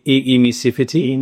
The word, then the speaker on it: emissivity: Can you please explain what, and I don't even know how to say this, that E in low E, emissivity.